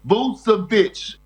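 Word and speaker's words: Vučević: It's Vucevic.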